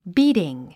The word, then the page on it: beating: bíːtiŋ; ビーティン